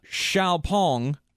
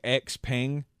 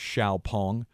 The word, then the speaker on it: Xiaopeng: We're on our way because Chinese electric vehicle company uh, Xiaopeng, or it looks like Xpeng, but I looked up the official pronunciation. Apparently, it is Xiaopeng.